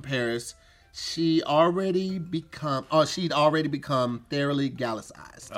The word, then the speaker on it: Gallicized: G-A-L-L-I-C-I-Z-E-D. Gallicized.